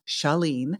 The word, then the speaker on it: Chalene: And that is spelled C-H-A-L-E-N-E, Chalene.